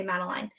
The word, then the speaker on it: Madeline: I don't know, because it's spelled, you can say it either way. You can say Madeline or you can say Madeline.